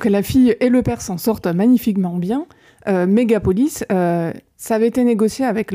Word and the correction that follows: Megalopolis: C'est pas Megalopolis plutôt ? – Megalopolis. – Ouais, je crois. – Il me manquait une syllabe, merci.